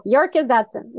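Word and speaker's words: Yerkes-Dodson: Yerkes Dodson? Yerkes? Yeah. – You got it. Yerkes Dodson.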